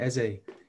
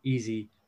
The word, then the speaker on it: Eze: I'm probably pronouncing his name wrong because... – No, yeah, I think you got it right. – You're on the money. – I saw a video of him when he signed going through how to pronounce his name because people would call him Easy.